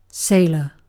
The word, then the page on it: sailor: British English